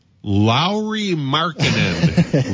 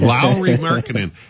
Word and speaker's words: Lauri Markkanen: We were talking before the show about Lauri Markkanen. Lauri Markkanen. It's spelled L-A-U-R-I, but I tweeted a video of him.